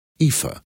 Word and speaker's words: Aoife: He had a wife and four children, but then his wife died, and he married her sister, Aoife, pronounced Aoife.